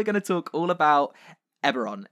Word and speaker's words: Eberron: I've always said it's Eberron, rising from the last war. – Yeah, see, I'm wrong. I always say... Eberron is right. I used to say Erebon.